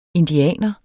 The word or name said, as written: indianer